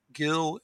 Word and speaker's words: Gil: People can also go to my Gil Baumgarten website. Gil is spelled with one L.